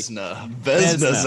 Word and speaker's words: Vezina: Am I even pronouncing that right? – Vezina. Zachary. – Well, listen. Here's the thing. Don't put an I in there. If it's Vezina, get the I out of there.